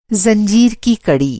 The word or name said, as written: कड़ी